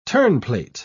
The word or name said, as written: terneplate